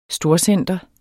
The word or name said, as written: STORCENTER